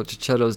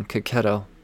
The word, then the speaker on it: Checchetto: So one of them he's chasing himself, and I mean, then Chip Zdarsky and Marco Checchetto's Daredevil. Oof. Sorry, I said that wrong. Checchetto. I say it wrong every time.